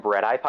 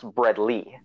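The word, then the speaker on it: Bredl: As long as you don't call them Breadeye pythons, which is something I've heard a lot of people call them. And I've actually been told by Scott Iper that should be pronounced Breadly, just based on how the nomenclature and taxonomy works.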